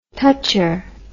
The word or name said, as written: toucher